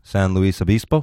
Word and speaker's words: San Luis Obispo: We were in the SLL Comedy Festival, San Luis Obispo. Is that how you pronounce it? – Yeah.